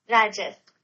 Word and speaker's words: Rajas: Raja. Rajas. Is that it? That's how you pronounce it? – Rajas.